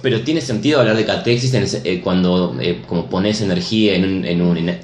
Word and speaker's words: catexis: Dice esta catexis, ¿se ya pronuncia así? ¿Catexis? – Sí, es palabras de las psiquiatrías de sí, está bien.